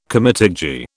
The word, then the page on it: comitadji: British English